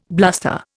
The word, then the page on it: bluster: Indian